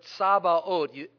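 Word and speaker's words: Tsabaoth: Because the Hebrew word here, Tsabaot, I'm just giving you the Hebrew pronunciation, it's T-S-A-B-A-O-T-H, Tsabaot.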